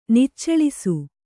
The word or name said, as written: ನಿಚ್ಚಳಿಸು